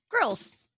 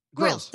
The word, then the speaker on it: Grylls: Grylls. – Grylls.